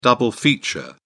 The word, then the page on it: double feature: British English